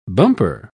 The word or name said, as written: bumper